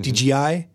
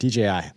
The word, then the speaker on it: DJI: What is the preferred pronunciation? Because I know it's DJI, but DJI is the worst. – DJI. I just say it like that. DJI. And I kind of elide it to a G.